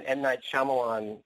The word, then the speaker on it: Shyamalan: Even M. Night Shyamalan, that's how you say his name, is creating a universe too.